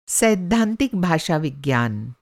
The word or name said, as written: सैद्धांतिक